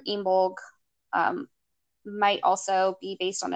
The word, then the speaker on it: Imbolc: So the internet says to pronounce it Imbolc, but it's spelled Imbolc.